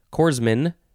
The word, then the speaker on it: corpsman: It is spelled C-O-R-P-S-M-A-N. I think that you could say corpsman, but it's the pronunciation guy says corpsman. So maybe that's what it is.